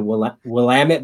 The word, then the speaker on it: Willamette: The Willamette Valley. Did I say it right? – Good job.